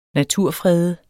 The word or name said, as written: naturfrede